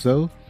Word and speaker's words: Zo: I'm your host, Zoe. That's spelled Z-O if you're in the U.S. or Z-O if you're outside of the U.S.